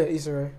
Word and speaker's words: Issa Rae: Look at Issa Rae. Did I say that right? – Yeah, Issa Rae.